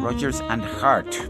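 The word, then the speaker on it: Rodgers and Hart: Rogers and Hart para pronunciarlo correctamente.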